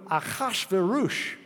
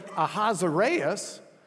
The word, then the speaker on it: Ahasuerus: So Ahasuerus is the way they were pronouncing the Shashayashashush. And then we get it into English, and the English translators say Ahasuerus, which isn't even close to the Hebrew, Ahasuerus, which isn't all that close to the Zesayasha, and Zesayasha, the Greeks had trouble with.